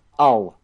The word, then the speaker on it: Hull: I don't really have an answer to that one, Rob, but I did actually miss the statue when I was in Hull, or as they pronounce it up in the north, Ull.